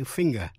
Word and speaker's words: finger: NG phát âm là g trong finger, ngón tay.